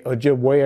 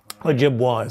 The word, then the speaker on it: Ojibwe: Okanagan, the Onondaga, and the Ojibwe, although since most people pronounce the vessel as Ojibwa, we say Ojibwe are the people. Ojibwa is the vessel.